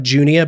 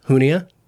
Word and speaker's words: Junia: It looks like Junia, but it's pronounced Hunia. J U N I A.